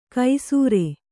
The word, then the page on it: ಕೈಸೂರೆ: kai sūre